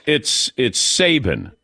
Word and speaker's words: Saban: Jumbo Fishy, Nick Saban, we're on to you. – Just want to say, Nick Saban. No, it's Saban.